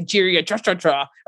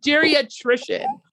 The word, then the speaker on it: geriatrician: Cause I used to say geriatra or geriatra. – Right. – And it'd be like geriatrician.